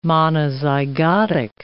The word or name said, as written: monozygotic